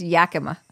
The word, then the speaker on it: Yakima: It's called Frostbitten, and it's from Yakima Valley, if I'm sure I'm saying that wrong. – I think it's Yakima.